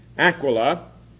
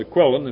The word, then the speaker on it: Aquila: Aquila is probably the more correct pronunciation, but because of the word in the Bible, we usually just call it Aquilon.